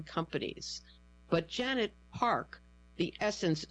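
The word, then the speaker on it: SSENSE: Quote, "It's a game changer," Rami Atala, the chief executive of Sense, pronounced Essence, said of the planned move in an interview.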